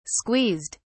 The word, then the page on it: squeezed: British English